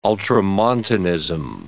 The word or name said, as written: ultramontanism